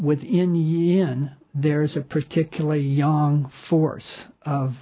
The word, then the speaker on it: yang: So one, so yang, and I'm mispronouncing yang. I should try to do a better job on that. But it's more pronounced as yang, Y-A-N-G.